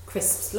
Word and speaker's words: crisps: Crisps. A lot of people find it so difficult to pronounce. Crisps. Now, crisps are cold.